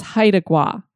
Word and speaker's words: Haida Gwaii: The way that I was saying it, Haida, it actually is Haida. So I believe it's Haida Gua. So I want to thank you, Jenna, for sending me that so that I know how to say it right in the future.